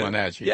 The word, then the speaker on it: Wenatchee: I think I've been pronouncing Wenatchee wrong. You say Wenatchee. – I think you pronounce it correctly. – I say Wenatchee. – I say it Wenatchee. – They're both acceptable.